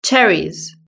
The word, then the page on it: cherries: British English